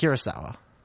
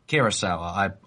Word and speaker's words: Kurosawa: Have I pronounced that right? – It's Kurosawa. – Kurosawa.